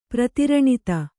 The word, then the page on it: ಪ್ರತಿರಣಿತ: Kannada